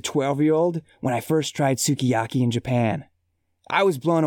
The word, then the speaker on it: sukiyaki: TJ writes, "The shabu shabu is divine, but I come for the sukiyaki and stay for the sukiyaki."